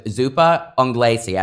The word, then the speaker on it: zuppa inglese: And the one thing I want you to go back to and tell a little bit of the differences is with zuppa inglese.